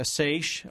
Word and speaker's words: seiche: And I think, and I'm sure you can correct me or give me the right word for this, a psyche or something like that. – Yeah, I think it's pronounced a seiche, although it's seiche. Spelled. – How's it spelled? – S-E-I-C-H-E. – That's it, seiche.